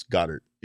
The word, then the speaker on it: Goddard: There was a fantasy draft pick that I took that I was calling him like Goddard, and people are like, it's Goddard. You know, it's just, you're not, you don't need to add that extra. You're not, he's not from a different country or anything like that. You're not pronouncing it right.